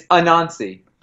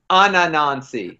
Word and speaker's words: Anansi: You're adding an extra syllable in there. – Is it not Anansi? – It's Anansi. I thought you were saying Ananansi. – Oh, no, just Anansi. There may be some lag with the Skype.